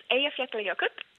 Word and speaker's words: Eyjafjallajökull: So it's Eyjafjallajökull.